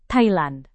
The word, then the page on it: thailand: Indonesian